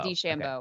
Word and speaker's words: DeChambeau: Bryson DeChambeau. Did I say that correctly? DeChambeau. – Yeah, DeChambeau.